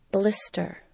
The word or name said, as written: blister